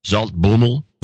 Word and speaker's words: Zaltbommel: Zaltbommel.